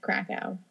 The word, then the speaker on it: Krakow: Auschwitz isn't too far from Krakow. It is about an hour's drive. I hope I'm saying that right. Krakow. – I think so. I think, yeah, I'm pretty sure.